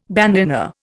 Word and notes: bandanna: Indian

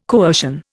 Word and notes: coercion: Indian

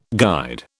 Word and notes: guide: British English

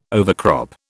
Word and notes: overcrop: British English

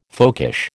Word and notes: folkish: American English

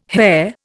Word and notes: hair: Indian

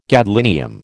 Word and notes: gadolinium: American English

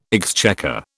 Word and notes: exchequer: British English

